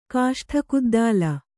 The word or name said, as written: ಕಾಷ್ಠಕುದ್ದಾಲ